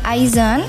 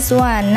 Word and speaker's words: IZ*ONE: Next another K-pop update ini ada dari girl band Aizen tapi bacanya yang benar adalah One.